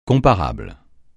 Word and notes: comparable: francais

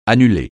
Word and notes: annuler: francais

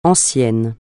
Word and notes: ancien: francais

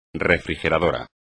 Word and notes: refrigerador: espagnol

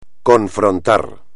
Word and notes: confrontar: espagnol

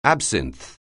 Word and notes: absinth(e): anglais

